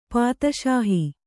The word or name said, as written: ಪಾದುಶಾಹ